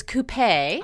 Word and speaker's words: coupé: First of all, I found out I was pronouncing it incorrectly. So it is coupé. – Oh. – It comes from the French.